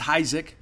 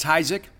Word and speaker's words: Heisick: I interviewed new baseball transfer Connor Heisick, and it is Heisick. I know it's phonetically you think it would be pronounced another way. It's Heisick. He tells me that he is open to play in whatever he needs to play position-wise.